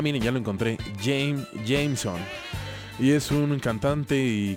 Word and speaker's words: JMSN: No sé cómo pronunciarlo, es JMSN y no le hallo como un, acrónimo, pensé que era como Jasmine, pero no.